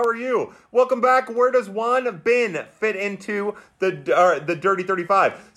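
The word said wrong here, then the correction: Won-Bin: So Win Bin, W-O-N, he actually pronounces it Win Bin Cho.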